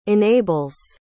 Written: enables

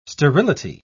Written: sterility

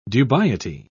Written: dubiety

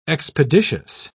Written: expeditious

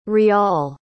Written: ryal

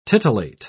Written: titillate

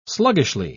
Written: sluggishly

